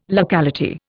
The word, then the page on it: locality: Indian